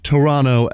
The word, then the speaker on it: Toronto: This week, the somehow second in the West Sounders FC take on the, you don't pronounce the second T, Toronto FC, in a battle for MLS Cup past in the present.